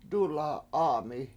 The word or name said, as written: dula7A7ami